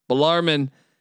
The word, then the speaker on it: Bellarmine: Jacksonville, my Dolphins, got their hearts broken in Louisville, Kentucky against Bellarmine. I said it right this time, damn it. Bellarmine.